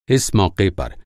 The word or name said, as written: मौक़ा